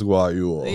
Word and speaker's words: guayule: in Salinas, it's pronounced Guayule. – Yeah. – Just like Rodeo.